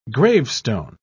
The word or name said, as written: gravestone